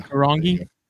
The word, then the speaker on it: Karongi: I, am not going to butcher it, but yeah, Karongi.